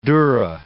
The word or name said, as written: dura